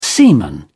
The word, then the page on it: semen: British English